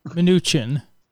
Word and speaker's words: Mnuchin: I always say his name wrong. How do you say it? – Mnuchin. – It is Mnuchin.